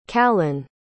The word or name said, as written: callan